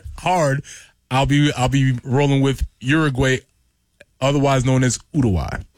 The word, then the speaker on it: Uruguay: Oh, and you pronounce it, and you actually pronounce it Uruguay. Yeah, that's how the natives pronounce it.